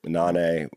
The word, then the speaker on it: Nané: If you want to honor Daniel, go and listen to his music, Nane, with an N-A or an accent on the E, N-A-N-E.